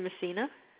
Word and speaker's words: Messina: It's Rita Messina.